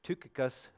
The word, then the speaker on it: Tychicus: Tychicus, or Tychicus, apparently is the pronunciation,